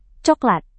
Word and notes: coklat: Indonesian

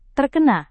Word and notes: terkena: indonesian

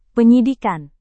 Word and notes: penyidikan: Indonesian

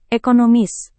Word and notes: ekonomis: indonesian

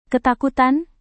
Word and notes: ketakutan: indonesian

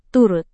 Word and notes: turut: indonesian

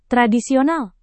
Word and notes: tradisional: Indonesian

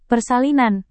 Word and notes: persalinan: Indonesian

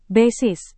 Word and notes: basis: indonesian

